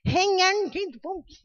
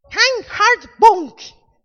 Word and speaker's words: Reinhard Bonnke: Reinhard Bonnke. Como é que é? Reinhard Bonnke.